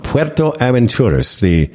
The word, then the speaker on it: Puerto Aventuras: Puerto Aventuras? Is that exactly how you say it? – Puerto Aventuras. Adventure port.